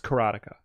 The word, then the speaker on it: Karateka: So is the game called Karateka? – Karateka. – Karateka. – It's pronounced Karateka.